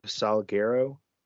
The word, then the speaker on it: Salguero: So apparently this is a real thing, but Armando, how do you say his name? Armando Salguero? – Correct.